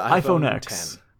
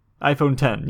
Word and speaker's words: iPhone X: So the iPhone X. iPhone 10, yes. Because it's not X, it's 10.